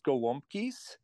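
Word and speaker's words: gołąbki: I don't know if we pronounced it right, the Polish growing up. Can you tell us about these Polish cabbage rolls? – Yes, you nailed it. It's pronounced gwumkis.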